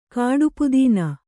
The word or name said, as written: ಕಾಡುಪುದೀನ